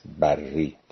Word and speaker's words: بری: نویسنده کتاب شخصی است به نام آقای باقر بری، ظاهرا تلفظ درست نام فامیلی ایشان این است، بری در نقطه مقابل مثلا بحری.